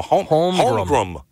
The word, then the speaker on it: Holmgren: that you're saying the guy's name wrong. It's, you know, Holmgren.